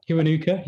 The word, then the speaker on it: Kiwanuka: But before we do that, we'll go on to your third song, Your Final Hero by Michael Kiwanuka. I don't know if I pronounced that right. Did I? – Kiwanuka, yeah. Yeah.